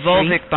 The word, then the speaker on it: Volvic: The Volvic bottle, then. That's V-O-L-V. V-O-L. It's pronounced just like V-U-L. – Right, Volvic. – Like a vulva.